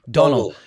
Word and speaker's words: Domhnall: How do you pronounce his son's name? – Donald. – Because in America, we always call him Domhnall, but I know that's incorrect.